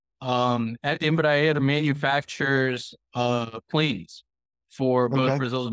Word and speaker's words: Embraer: Embraer? – Yes, that's exactly right.